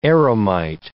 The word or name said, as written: eremite